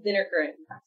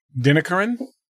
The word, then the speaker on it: Dinnikaran: It's pronounced Dinnikaran. So it's Aparna and Ishwaran Dinnikaran. – Dinnikaran? – Dinnikaran. – Okay. – The, like the word the in the front. So Dinnikaran.